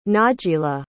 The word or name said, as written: najila